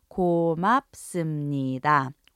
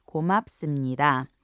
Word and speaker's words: 고맙습니다: Once again, it's pronounced 고맙습니다. 고맙습니다. In most cases where you would say thank you in English, 고맙습니다 or its otherwise conjugated forms would suffice.